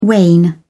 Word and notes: wane: British English

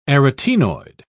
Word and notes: arytaenoid: British English

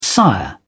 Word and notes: sire: British English

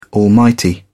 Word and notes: almighty: British English

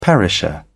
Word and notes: perisher: British English

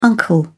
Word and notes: uncle: British English

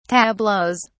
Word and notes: tableaux: British English

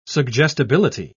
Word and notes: suggestibility: British English

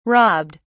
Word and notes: robbed: British English

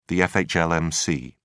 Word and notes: fhlmc: British English

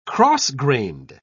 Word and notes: cross-grained: British English

